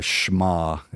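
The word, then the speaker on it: Shema (שמע): So the Shema, it's called in verse 4 of Deuteronomy 6, it's called a Shema, if you want to be Shema more technically accurate in your pronunciation in Hebrew.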